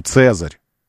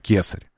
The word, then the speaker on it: Цезарь: «К» не было у них, «С» было. Поэтому «digitus impodicus». Ну, не было у них произношения на «К». «К». Было «С». Поэтому «Цезарь», а не «Кесарь».